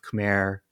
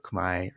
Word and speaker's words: Khmer: And in the spelling in the language itself, there is a ro, there is an R at the end. And so when speaking in English, both Khmer, sort of the normal English pronunciation, or Khmai are both perfectly fine.